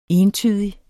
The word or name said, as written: entydig